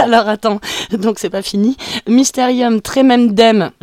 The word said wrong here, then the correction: Mysterium Tremendum: je sais pas si je le prononce bien. – Bah non, mais c'est pas grave. – Alors vas-y, prononce-le pour nous, enfin pour moi. – Mysterium Tremendum. – Tremendum, ok.